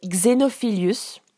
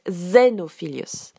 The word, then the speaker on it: Xenophilius: En français, il s'appelle Xenophilius et en, anglais, on prononce Xenophilius. Donc Xenophilius devient Xenophilius.